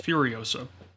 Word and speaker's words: Furiosa: Hold on, how do you say that first one? Furosa? Well, Furiosa.